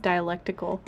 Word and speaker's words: dialectical: I say dialectable. Can you help me out? – Oh, I mean, you're not terribly off, just dialectical.